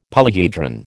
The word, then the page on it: polyhedron: American English